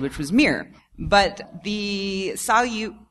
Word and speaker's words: Salyut: It's called the, is it pronounced Salyut? – Salyut, I think. – Salyut. Well, it's the Russian word for salute.